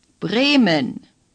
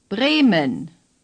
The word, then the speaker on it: Bremen: Say, Bremen. – The first E is long A. The second is short and barely pronounced, similar to the E sound in the English word omen. – Bremen.